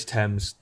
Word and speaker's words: Thames: On the river, was it River Thames or River Thames? I don't know how to pronounce it. – It's Thames. – Thames, okay.